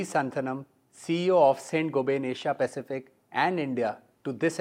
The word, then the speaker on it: Saint-Gobain: I understand the right pronunciation is something like Saint-Gobain.